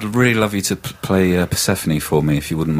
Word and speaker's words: Persephone: tells me should be pronounced Persephone.